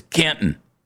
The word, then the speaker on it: Canton: You know, it's like a, it's not necessarily a silent T. I call it a soft T. It's Canton, you know? It's not Canton.